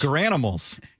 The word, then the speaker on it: Garanimals: And Swigard quote tweeted it with, am I pronouncing this right, Garanimals? – Garanimals.